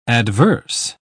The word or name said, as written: adverse